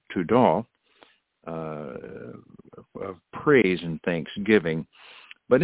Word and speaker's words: todah: It is pronounced todah, which means an extension of the hand in worship, or a sacrifice of praise and thanksgiving.